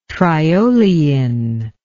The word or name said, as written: triolein